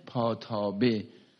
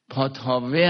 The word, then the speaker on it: پاتابه: اینو میگن پاتابه. پاتاوه هم با واو هم تلفظ میکنن.